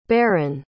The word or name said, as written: Berrin